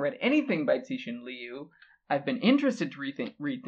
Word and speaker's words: Cixin Liu: It is Ball Lightning by, and I looked up the pronunciation, it's Cixin Liu. – Okay. – Cixin Liu. Spelled C-I-X-I-N-L-I-U.